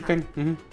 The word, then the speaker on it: Keen: Ajá, ah ya, se llama Ken, no sé si está bien pronunciado, creo que es Ken, K-E-E-N. – ok, sí, Ken.